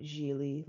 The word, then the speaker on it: Gigli: Last movie I watched was Gigli, spelled like giggly, but it's pronounced Gigli, like really, with J-Lo and Ben Affleck.